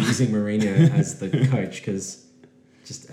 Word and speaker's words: Mourinho: It had Jose Mourinho, which I thought, Mourinho, I should say.